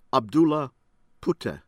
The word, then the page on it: Abdullah Puteh: American English